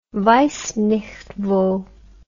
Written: weissnichtwo

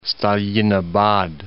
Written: Stalinabad

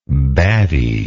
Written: battiness

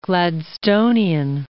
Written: Gladstonian

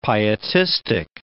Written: pietistic

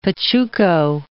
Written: pachuco

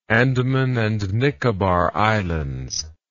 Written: Andaman and Nicobar Islands

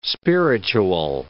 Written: spiritual

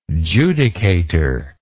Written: judicator

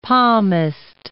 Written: palmist

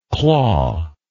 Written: clawless

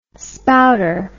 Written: spouter

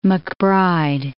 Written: MacBride

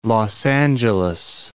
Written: Los Angeles

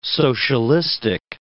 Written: socialistic

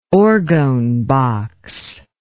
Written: orgone box

